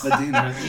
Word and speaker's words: Badinerie: Badinerie. – You just kind of cough out the last vowel.